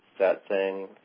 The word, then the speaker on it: satsang: I understand you do something called satsang. How you pronounce that? I'm probably saying it again wrong. Satsang. What's that? – Satsang. Yeah.